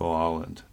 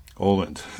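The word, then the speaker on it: Aaland: It's A-A-L-A-N-D, Mikkel Åland. Åland. See, I want to say it the way that it's spelled, but it's really Mikkel Åland, but he spells it Åland.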